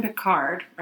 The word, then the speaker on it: Picard: Captain Picard.